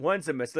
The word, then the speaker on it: Onesimus: How would you pronounce this guy's name? – I would call it just Onesimus. – Onesimus.